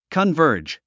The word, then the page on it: converge: kənvə́ːrdʒ; カンバージ